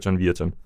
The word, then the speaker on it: Virton: Mod Royal Excelsior Virton. Jeg kalder dem bare Virton. – Bedre kendt som Virton, ja. – Ja, Virton.